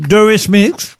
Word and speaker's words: Eurythmics: Eurythmics. Je le dis bien. – Très bien. – Eurythmics.